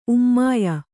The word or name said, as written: ಉಮ್ಮಾಯ